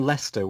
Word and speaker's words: Leicester: I am originally from Leicester, which is in the Midlands of England and is not at all spelt how it's pronounced.